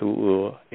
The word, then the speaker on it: Oeur: Or you can just Google my name, Chakra U or Chantu U. It's spelled Chanthou, last name Oeur, or Chakra, last name Oeur.